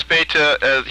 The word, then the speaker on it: Bethe: Oh, Hans Bethe? – Maybe I'm pronouncing his name wrong. – He pronounces, is it B-E-T-H-E? – That's it. – Yeah, Hans Bethe, he pronounces it, Bethe.